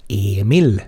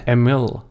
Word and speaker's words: Emil: Mitt, mitt ex är från Storbritannien och det tog väl honom tre månader ens uttala Emil korrekt liksom, så som man gör här. Emil. Emil.